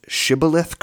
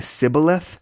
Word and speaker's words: Shibboleth: If the person is not able to pronounce Shibboleth correctly, instead saying Sibboleth, Jephthah and his men slaughter that fugitive.